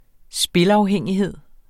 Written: spilafhængighed

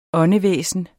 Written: åndevæsen

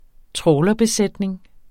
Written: trawlerbesætning

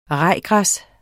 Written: rajgræs

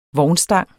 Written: vognstang

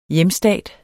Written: hjemstat

